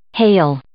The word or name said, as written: hale